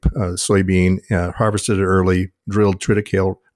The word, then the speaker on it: triticale: So for me, I use triticale, or as some people pronounce it, depending on what part of the country you're in, triticale.